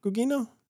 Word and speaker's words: Gugino: Is it Gugino? Gugino? Am I pronouncing that right? – Right, yeah.